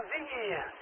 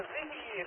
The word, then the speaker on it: Singi: Ah, Singi, Sierra India Golf, Golf India, Singi, lang ausgesprochen, Siegfried.